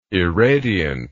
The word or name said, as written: irradiant